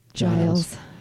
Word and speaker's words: Giles: I even wrote it down as a J because that would be Julia, Giles, J, Jelly.